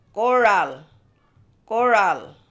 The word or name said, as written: Gooral